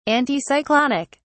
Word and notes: anticyclonic: British English

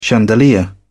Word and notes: chandelier: British English

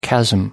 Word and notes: chasm: American English

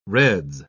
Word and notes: reds: British English

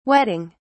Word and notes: wetting: British English

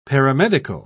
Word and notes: paramedical: British English

